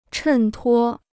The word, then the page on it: 衬托: chèn tuō